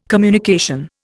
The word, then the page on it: communication: Indian